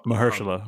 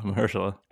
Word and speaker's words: Mahershala: I'm always going to say his name. I'm going to say Ali. – Mahershala. Mahershala. – Mahershala. – Let's practice it together.